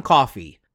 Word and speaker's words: Coffey: But when the movie starts, a third inmate arrives, a very large man named John Coffey, pronounced like the drink but not spelled the same, played by Michael Clarke Duncan.